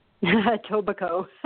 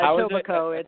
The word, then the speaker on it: Etobicoke: Etobicoke, but you were very close. – Oh, okay. – Very impressive. Yeah, Etobicoke. It's Etobicoke. – Okay. – The K is silent.